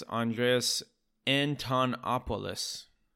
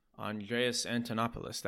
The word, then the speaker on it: Andreas Antonopoulos: Andre Antonopoulos. Uh, f- yeah, it's Andreas Antonopoulos. Andreas Antonopoulos, that's right.